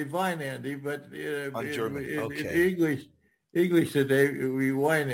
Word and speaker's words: Weinandi: But so I suppose if you were going to pronounce it, it'd be Vine Andy. But we don't live in a German culture, so it comes out Wine Andy.